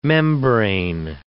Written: membrane

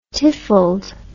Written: tiffled

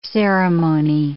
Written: ceremony

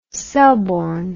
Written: selborne

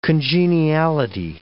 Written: congeniality

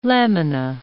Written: lamina

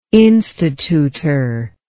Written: instituter

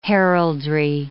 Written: heraldry

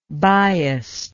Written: biased

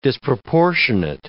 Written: disproportionate

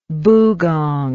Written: bugong

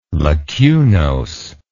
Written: lacunose

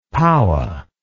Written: paua

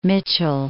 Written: Mitchell, Mount

